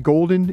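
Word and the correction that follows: Pete Golding: is that how you say it? – Golding is the coach at Ole Miss now.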